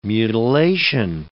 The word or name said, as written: mutilation